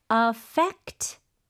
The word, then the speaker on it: affect: Affect 목적어. Affect 목적어. 여기서 스펠링 주의하셔야 돼요. AFFECT입니다. – 아, 이거는 동사로 쓰게 된 거네요. – 네, 그렇죠? – 맞아요. – Right. So FE에 강세를 주면서 affect 뭐 someone something, right?